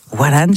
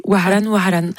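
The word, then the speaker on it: Waran (وهران): Waran, je prononce mal. Vas-y. – Tu étais vraiment très proche. Waran, qui veut dire Oran.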